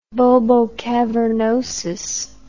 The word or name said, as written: bulbocavernosus